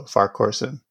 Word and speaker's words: Farquharson: It's Farquharson Gaboa. So Farquharson. – Hmm. – Oh, I was adding too many syllables then.